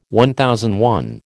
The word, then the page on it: MI: American English